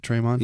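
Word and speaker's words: Tremon: yeah, so, yeah, because Smith, not going to attempt to pronounce his first name. – Traymon? – Is it Traymon? – Yeah, just Traymon. It's just spelled goofy.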